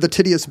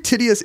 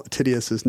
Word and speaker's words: Titius: I believe Titius is the right pronunciation of Johann Daniel Titius's name. – It's not Titius. – I don't believe so. Here's the deal. He's German, and his name is actually something like Tietze, I think.